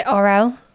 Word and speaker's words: Arell: Most of the time, I pronounce it Arell.